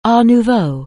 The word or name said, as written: Art Nouveau